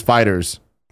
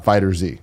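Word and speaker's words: FighterZ: And Dragon Ball Fighters is Fighters, like it's in the 90s. So not Fighter Z, it's Fighters.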